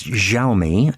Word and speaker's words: Xiaomi: Yes, this is Xiaomi, as it's pronounced, which has been making phones for a while now.